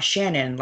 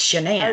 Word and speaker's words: Shannan: But for those of you who don't, so I might butcher her name because it is not Shannon like I thought it was. It's Shannan. – No, it's not. – It's Shannan.